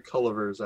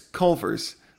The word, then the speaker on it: Culver's: Well, I would have enough to go to like a Culliver's afterwards, so I guess that's what. – You keep saying Culliver's. It's Culver's. There's no third. It's a two-syllable word.